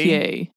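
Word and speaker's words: Gotye: It's either pronounced Gotya, like I gotcha, or Gautier or Gautier. – It's Gautier. – Gautier, like it's I'm growing a Gautier on my face.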